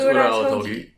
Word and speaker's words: Ura-Otogi: Yeah, it's Ura-Otogi.